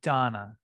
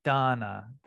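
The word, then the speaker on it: dāna: Dana. Yeah, there's a long A. Thank you. Dana.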